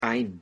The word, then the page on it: ein: German